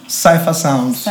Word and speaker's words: Cipha Sounds: How do you say his DJ name? – Cipha Sounds. – Cipha Sounds.